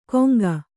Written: ಕೊಂಗ

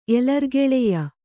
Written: ಎಲರ್ಗೆಳೆಯ

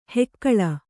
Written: ಹುಗುಳು

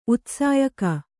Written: ಉತ್ಸಾಯಕ

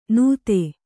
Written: ನೂತೆ

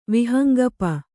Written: ವಿಹಂಗಪ

